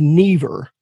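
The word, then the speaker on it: Neever: That skeleton includes we're in a world, and I'm going to call it the Lands of Never. And usually pronunciation is all that matters, but just in case somebody cares how it's spelled, it's N-E-E-V-E-R. It's like never with an extra E.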